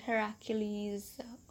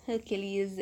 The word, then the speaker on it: Heracles: So they have this gathering of all these great heroes, people who fought with Heracles, or Hercules if you don't want the Greek pronunciation, and all these great warriors and seers and whatnot, and they all come to try and fight for Helen's hand.